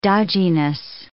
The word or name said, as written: dodginess